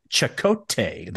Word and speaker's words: Chekote: They spell it C-H-E-K-O-T-E, but it's definitely Chakotay, the way they pronounce it.